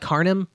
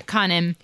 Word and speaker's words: Canim: It sounds to me like Khanim, but it's spelled C-A-N-I-M. So yeah, Khanim.